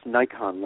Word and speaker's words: Naikan: It was in the first part, and it's help me pronounce, it's Nikan, N-A-I-K-A-N. How do you pronounce that? – It's actually pronounced Nikon, like the camera.